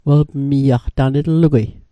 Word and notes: welpmiaq: Mi'gmaq

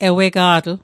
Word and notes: ewega'tl: Mi'gmaq

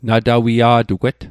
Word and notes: natawia'tugwet: Mi'gmaq

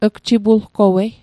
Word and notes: gjipulgowei: Mi'gmaq

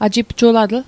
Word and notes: ajipjulatl: Mi'gmaq

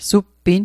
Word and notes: suppin: Mi'gmaq

